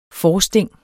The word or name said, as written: forsting